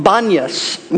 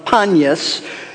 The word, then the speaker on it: Banyas: It is called Banyas, which is the Arabic pronunciation of Panyas, and that's a name related to the ancient god Pan.